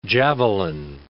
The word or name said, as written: javelin